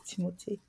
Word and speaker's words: Timothée: I saw a video of him where he was like, oh, his name is actually pronounced Timothée.